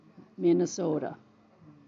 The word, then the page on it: Minnesotan: Native